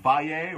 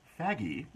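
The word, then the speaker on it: Fage: The old faye, or if you prefer, faggy yogurt. It's funny, on the package it says pronounced faye with a Y. Oh, it's F-A-G-E.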